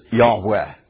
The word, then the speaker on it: Yahweh: what he's presenting is Yahweh.